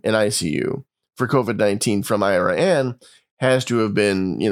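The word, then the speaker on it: Iraan: It's spelled almost like the country Iran. It's I-R-A-A-N, but it's pronounced Iran, Texas.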